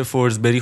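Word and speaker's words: Forsberg: ببین کلا سوئدی جی توش داستان داره مثل فورزبرگ که فورزبری خونده میشه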